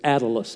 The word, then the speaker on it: Attalus: Not spelled like Atlas, Atlas. As we know it, it's Attalus, A-T-T-A-L-U-S, had a brother that he had great affection for.